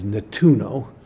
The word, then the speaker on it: Nettuno: Uh, the Italian pronunciation is Nettuno, but it's me.